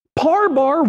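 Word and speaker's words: parbar: Par bar was the Hebrew word. That's how you pronounced it.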